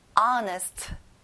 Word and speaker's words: honest: 친구들 앞에 어에 강세를 넣어보는 건데요. 앞에 H 발음은 안 나요. Honest.